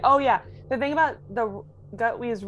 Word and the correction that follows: Gatwa: role, am I saying that right? – Gatwa. – Gatwa, thank you.